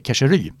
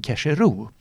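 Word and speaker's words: Keșerü: Ja men exakt, här, och, då, och, då, fick jag alltså, jag fick alltså en direkt meddelande om att det ska uttalas eh, Keshery. Men grejen var, jag hade ju suttit, och detta är alltså inför matchen sitter vi alltså på ett uttalsmöte med eh, rumänska tvn och går igenom varje namn. – Och då sa de att det egentligen ska vara, men vi säger i Rumänien Kesheru.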